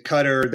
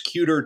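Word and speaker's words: Kuter: There are various ways that people say it, and I was able to discover in the archives that is he was very emphatic that it was cuter to rhyme with pewter.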